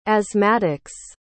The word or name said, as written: asthmatics